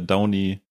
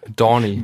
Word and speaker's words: Downey: allerersten Szene äh, Sherlock, gespielt von Robert äh, Downey, ich wollte schon wieder absichtlich falsch aussprechen. Dorney.